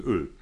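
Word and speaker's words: Øl: Nee, dieses, dieses O mit, mit Querstrich durch und dann L. Okay. Ausgesprochen Öl.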